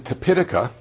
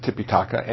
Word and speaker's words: Tipitaka: Okay, I pronounce it Tipitaka, but it can be pronounced Tipitaka, and that is the Buddhist literature that's called the Three Baskets.